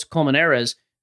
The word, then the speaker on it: Colmenares: So like Carlos Colmenares, your pronunciation, I think that's actually spot on, by the way.